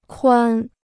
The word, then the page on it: 宽: kuān